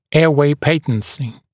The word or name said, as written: airway patency